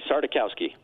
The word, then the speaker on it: Sardakowski: It's Sarda like a cow on skis. That's what I tell everybody. Sardakowski.